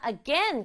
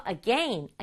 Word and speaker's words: again: 그리고요 친구들 마지막 단어 again 같은 경우에는 사람에 따라서 again이라고 발음하는 사람도 있고 선생님처럼 again이라고 발음하는 사람도 있어요. 친구들도 again, again, again, again 두 가지로 발음해보면서 나한테 맞는 발음 찾아봐요.